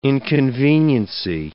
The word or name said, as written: inconveniency